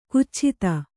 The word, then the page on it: ಕುಚ್ಛಿತ: kannada